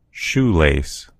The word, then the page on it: shoelace: American English